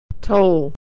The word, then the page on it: toll: American English